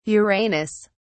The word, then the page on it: uranus: British English